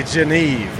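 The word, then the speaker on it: Genève: Or as the locals say, Geneve, right?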